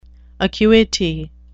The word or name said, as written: acuity